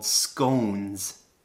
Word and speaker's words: scone: A tea biscuit is kind of like in the UK, they have these things called scones. I think some people say scone. You can pronounce it both ways, I think. Don't quote me on that. Scone or scone.